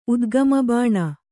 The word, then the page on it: ಉದ್ಗಮಬಾಣ: Kannada